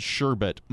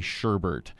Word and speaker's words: sherbet: I'm going to go ahead and say it the way it's spelled and say that this word is supposed to be pronounced sherbet, but I'm doing it under a little bit of protest because I think it ought to be sherbert.